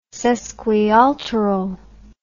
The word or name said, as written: sesquialteral